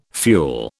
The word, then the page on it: fuel: British English